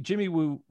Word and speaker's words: Jimmy Woo: Jimmy Woo rhymes with Scooby-Doo, which was the X-Files before there you go.